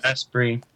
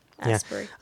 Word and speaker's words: Asprey: It's Asprey. – Asprey.